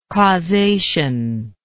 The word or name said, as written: causational